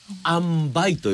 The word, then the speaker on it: 塩梅: 塩梅と書いてあんばいと読むんでございますね、うん、はい。